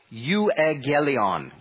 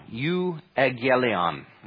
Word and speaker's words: euangelion: And you'll see there the Greek word, which is usually pronounced euangelion, euangelion, okay?